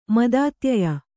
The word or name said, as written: ಮದಾತ್ಯಯ